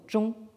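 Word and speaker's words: zhong (中): Vous savez tous que le pays du milieu, c'est la Chine, Zhongguo, le pays du milieu, etc ça désigne donc la centralité au premier ton, zhong. Mais si vous le prononcez au quatrième ton, zhong, hein, euh, hein, euh, euh, ça vous indique qu'il s'agit d'un verbe.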